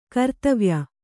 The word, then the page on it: ಘ್ರಾತವ್ಯ: ghrātavya